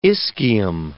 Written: ischium

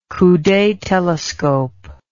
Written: coude telescope

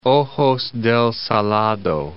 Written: Ojos del Salado

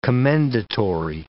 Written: commendatory

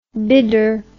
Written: bidder

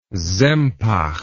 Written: Sempach

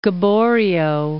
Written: Gaboriau

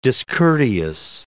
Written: discourteous

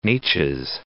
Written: Neches

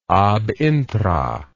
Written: ab intra

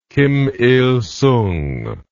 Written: kim il sung

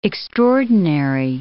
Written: extraordinary

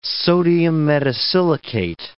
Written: sodium metasilicate

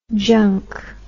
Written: junque

